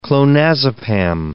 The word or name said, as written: clonazepam